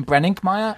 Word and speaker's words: Brenninkmeyer: Well, it's really weird because when I first came to America and I said my name, Brenninkmeyer, all America, they would go, oh, or I'd spell it to someone, they would go, oh, Breckenmeyer. So always Breckenmeyer, always.